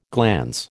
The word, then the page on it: glans: American English